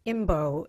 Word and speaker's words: Imbeault: And the author is Robert Imbeault, and that is spelled Imbeault.